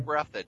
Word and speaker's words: Breathed: Breathed Berkeley or however you pronounce his name? – Breathed.